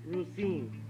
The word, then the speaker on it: Rosine: Rosine. – How do you spell it? – R-O-S. – Uh-huh. – I-N-E.